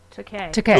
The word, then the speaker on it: Takei: What it, George Takaki. – Takei. – George Takei. Yeah. – It rhymes with gay.